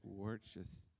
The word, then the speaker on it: Worcestershire: Worcestershire. That's right. I said it right.